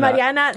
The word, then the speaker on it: Mariana: Mariana, the way Chase liked to say it. He pronounced it correctly.